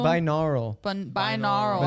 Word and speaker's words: binaural: Binaural. I've been saying that wrong for a long time.